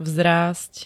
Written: vzrásť

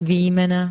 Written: výmena

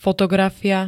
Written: fotografia

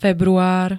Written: február

